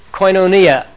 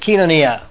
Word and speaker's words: koinonia: And churches all over the world have their little groups that go by the word for fellowship, koinonia, or as the modern Greek would pronounce it, kinonia. K-O-I-N-O-N-I-A, koinonia.